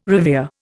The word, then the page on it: revere: Indian